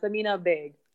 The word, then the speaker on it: Samina Baig: And so your friend from Pakistan was Samina Baig. Did I say that correctly? – Yeah, Samina Baig.